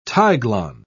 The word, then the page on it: tiglon: British English